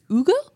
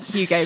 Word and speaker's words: Hugo: And it's actually pronounced, I think it's Ugo. No, you don't say it like Hugo, because I had a lot of strong lucks when I went in and I was like, "Can I have a Hugo?"